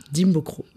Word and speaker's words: Dimbokro: Et du coup, on va revenir sur tes débuts parce que tu es né à Dimbokro. – Dimbokro.